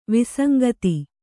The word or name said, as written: ವಿಸಂಗತಿ